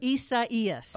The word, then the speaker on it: Isaias: And now Tropical Storm Isaias, I think that's how you say it. – Isaias. – Oh, I wasn't even close.